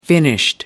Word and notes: finished: American English

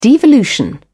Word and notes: devolution: British English

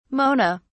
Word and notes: mona: British English

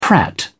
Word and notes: prat: British English

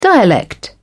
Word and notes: dialect: British English

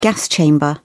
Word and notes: gas chamber: British English

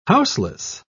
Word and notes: houseless: British English

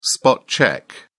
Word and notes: spot-check: British English

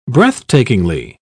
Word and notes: breathtakingly: British English

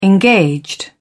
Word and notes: engaged: British English

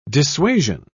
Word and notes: dissuasion: British English

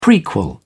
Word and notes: prequel: British English

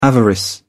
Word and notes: avarice: British English

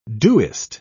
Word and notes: doest: British English

